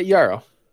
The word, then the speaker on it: Juraj: Yeah, Yaro. – Yeah. Is that how it is? How it's pronounced? – Just J-U-R-A-J.